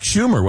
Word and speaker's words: Schumer: I believe that was Chuck Schumer, was it not? – Yes. – Yeah. – Senator's name rhymes with rumor.